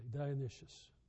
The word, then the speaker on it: Dionysius: I've worked at how to pronounce that. I have it written out phonetically here. I really think that's pronounced appropriately, Dionysius.